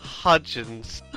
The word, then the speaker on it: Hudgens: Hudgens.